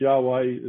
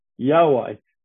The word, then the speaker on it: Yahweh: We think it's pronounced Yahweh as best we know.